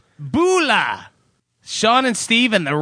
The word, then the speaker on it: Bula: Bula, and if you happen to be from the Fiji Islands, please correct me if I'm pronouncing that wrong. Bula is Fijian for hello. Bula. You learn lots of shit on Stuck in the 80s, don't you? – I'm pretty sure Kevin Wunsch says that's pronounced Bola.